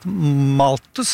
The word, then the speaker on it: Malthus: lest Malthus som du uttalte riktig tror jeg denne,